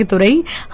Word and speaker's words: तोरई: और ऐसी बहुत सारी चीजें जो रशीद खान की भी लंच में बनती है ना, तो वो हमें बता देते हैं कि तोरई, जैसे कि तुरई, हम तुरई बोलते हैं, but the I think the actual pronunciation of तोरई इज तोरी।